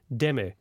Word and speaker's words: Demme: And I must admit, I never knew that's how you pronounced his name, but Jonathan Demme, who passed away end of last week.